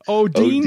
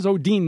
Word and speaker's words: Odene: O-D-E-N-E, Odine Dean. – Yeah, I ran upstairs and asked my wife, how would you pronounce Odine? It's so it is Odine, not Odine, not Odin. – Right. Oh, O-D-E-N-E.